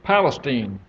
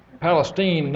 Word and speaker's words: Palestine: And I'm from Palestine. That's a town in the East Texas area. And it is pronounced Palestine, not Palestine.